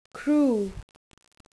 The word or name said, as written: crew